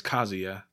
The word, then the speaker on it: Kazuya: We used to always say Kazuya or Kazuya back in the days. We didn't know how to pronounce it, but it's Kazuya.